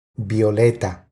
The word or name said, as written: Violeta